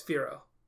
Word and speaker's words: Firo: It's Firo. F-I-R-O, and it's most certainly pronounced Firo. – I wrote down A, F-A-R-O.